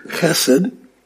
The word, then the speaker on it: Chesed: Those of you who are taking notes, Chesed. The ch presents that guttural sound at the beginning, Chesed.